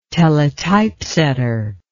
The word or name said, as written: teletypesetter